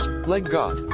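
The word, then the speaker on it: leg godt: Leggot. – Leggot. Leggot, the 2 words, leg and got, which means play well in Danish.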